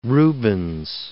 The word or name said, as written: Rubens